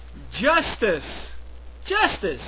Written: justice

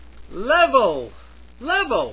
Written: level

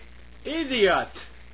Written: idiot

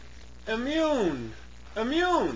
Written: immune

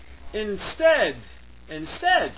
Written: instead

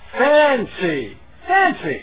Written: fancy

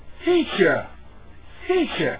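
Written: feature

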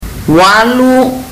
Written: walu

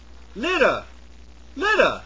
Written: litter

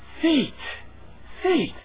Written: feat

